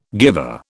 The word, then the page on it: guiver: British English